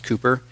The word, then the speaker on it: Cowper: William Cowper, it's spelled Cowper, but it's pronounced Cooper.